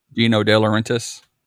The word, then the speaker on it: Dino De Laurentiis: Is it Dino De La, how do you say it? – Dino De Laurentiis.